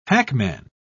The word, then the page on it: hackman: British English